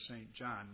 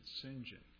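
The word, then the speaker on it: St John: Now, if you saw his last name, you would think it was St. John, but being a Britisher, they pronounced it St. John.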